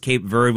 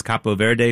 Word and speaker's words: Cape Verde: And yes, it is pronounced Cape Verde when you say it in English. It's Cabo Verde if you say it in Spanish.